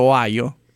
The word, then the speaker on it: Ohio: Perché ho detto Ohio? – Sì, hai detto bene.